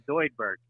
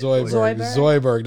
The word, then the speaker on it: Zoidberg: Zoidberg, that's how you say it. – Zoidberg, that's exactly right.